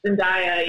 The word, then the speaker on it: Zendaya: I didn't want to like it at first, but Everybody's talking about Zendaya. Is that how you pronounce her name? – Zendaya. Yeah.